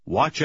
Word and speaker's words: watch: Сочетание букв TCH имеет такое же произношение. Буква T не произносится. Прочитайте номер 6. – Watch out!